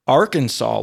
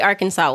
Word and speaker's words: Arkansas: Arkansas law regulates the pronunciation of the state's name, stating that the only true pronunciation is that received by the French from the Native Americans and is pronounced in three syllables with the final S silent, the A in each syllable with the quote-unquote Italian sound, and the accent on the first and last syllables.